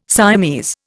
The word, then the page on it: Siamese: Indian